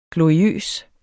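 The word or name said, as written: GLORIØS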